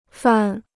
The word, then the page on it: 犯: fàn